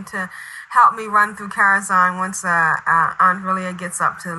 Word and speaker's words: Karazhan: He's up there raiding with in Karazhan. Am I saying that right? – Yep, it's one of our raid leaders.